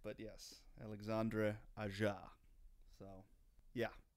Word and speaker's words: Alexandre Aja: This episode, we're going to be discussing the new Gator creature feature, Crawl, directed by a man whose name I have come to find out I've been pronouncing all wrong. It's Alexandre Aja. – I don't even know my own name today, evidently. So Alexandre Aja. I believe I said aha in the in the preview message at the end of the last episode.